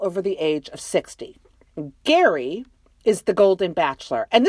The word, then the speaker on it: Gerry: It's spelled G-E-R-R-Y, which in my mind is Jerry, but they all pronounce it Gary. So we'll go with it. We'll just say Gary.